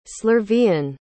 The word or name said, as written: slurvian